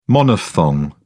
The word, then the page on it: monophthong: British English